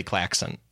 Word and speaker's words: klaxon: Hey, I quickly Googled, and Google had a little pronunciation thing. It is definitely klaxon. I have been saying it strange for my entire life.